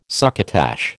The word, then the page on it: succotash: American English